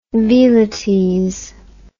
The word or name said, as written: velites